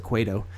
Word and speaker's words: Cueto: It was supposed to be a pitcher's duel because it was Jake Arrieta versus Johnny Cueto, or Cueto. I guess you pronounce it Cueto.